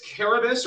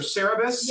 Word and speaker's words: Cerberus: It's Cerberus or Cerberus, Cerberus. – Yeah, and you'll hear it pronounced different ways because we anglicized the Greek.